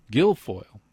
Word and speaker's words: Guilfoyle: Guilfoyle.